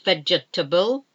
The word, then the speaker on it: vegetable: Um, vegetable, vegetable, not vegetable. It's vegetable. Bridge, bridge.